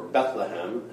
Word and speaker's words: Bethlehem: And in fact, the, the word Bethlehem or Bethlehem, as we say, we pronounce the H, uh, TH, um, means house of bread.